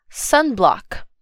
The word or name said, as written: sunblock